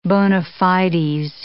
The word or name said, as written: bona fides